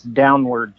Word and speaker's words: Downward: It's Downward, just like Downward Spiral.